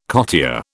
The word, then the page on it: cottier: British English